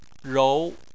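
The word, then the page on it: rou: tone 2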